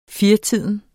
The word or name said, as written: firetiden